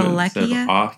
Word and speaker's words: Wallachia: Am I saying that wrong again? – You're using like an S sound. – Wallachia? – Wallachia.